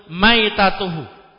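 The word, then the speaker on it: Maitatuhu (مَيْتَتُهُ): Nah, qala Rasulullah sallallahu alaihi wasallam fil bahri wattahur ma'uhu al-khillu maitatuhu. Maitatuhu bi fathil mim. Maitatuhu bi fathil mim. Ma lam talhaqhu Az-zakatu syar'iyah. Ma lam talhaqhu az-zakatu asy-syar'iyah. Maitah itu mimnya di fathah bacanya ya.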